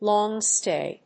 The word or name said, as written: long stay